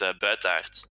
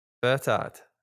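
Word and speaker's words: Buytaert: Last name is even more difficult. It's Bertaert. – Bertaert.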